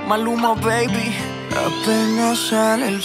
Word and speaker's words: Maluma: Bueno, vamos a escuchar un tema de Maluma. De Maluma. – Maluma. – Bueno, no me sale pronunciar. – No, Maluma.